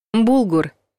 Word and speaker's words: булгур: Полезная штука, а ударение нужно ставить на последний слог — булгур.